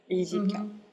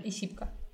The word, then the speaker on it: ISIPCA: Ну, по-французски, если читать, то это Z. И зипка. Угу. – Вот, теперь мы знаем хотя бы, как произносится самая крутая школа, а то по-русски все говорят «Исипка».